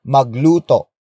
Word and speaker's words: magluto: And that adverb is magluto. Magluto. Magluto. Magluto is spelled as M-A-G-L-U-T-O.